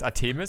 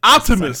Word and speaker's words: Artemis: Und zwar, es heißt Artemis. – Nein, Artemis. Du musst das richtig aussprechen. Artemis, Artemis okay. Mann. Das ist ein okay, Gott. Das ist ein griechischer Gott.